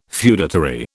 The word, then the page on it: feudatory: British English